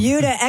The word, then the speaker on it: Buda: It's called the Buda. – Like Peter Wolf. – It's spelled B-U-D-A, and I looked it up. It is pronounced like beauty.